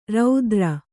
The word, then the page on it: ರಾತ್ರೆ: rātre